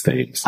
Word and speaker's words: Thames: I remember earlier you were saying, like, how do we pronounce, is it Thames? And I was like, I thought it was Thames. – I missed an opportunity to tell you, yes, it's Thames.